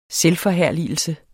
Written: selvforherligelse

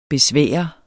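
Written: BESVÆRET

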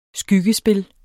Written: skyggespil

